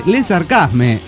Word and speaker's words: Le Sarcasme: Le sarcasme, o sea, nada de tono francés. Es parte de la onda de la banda que el nombre suene así, bien crudo. Le sarcasme, nada de le sarcasme.